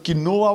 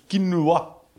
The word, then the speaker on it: quinoa: Voor iedereen die dacht dat het quinoa was, het is dus quinoa.